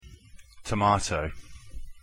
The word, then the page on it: tomato: English